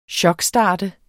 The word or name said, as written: chokstart